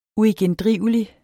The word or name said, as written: uigendrivelig